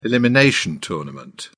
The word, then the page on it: elimination tournament: British English